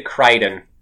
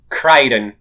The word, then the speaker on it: Crichton: I've never pronounced the C. I just say Crichton. Crichton. – Michael Crichton.